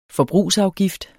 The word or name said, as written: FORBRUGSAFGIFTERNES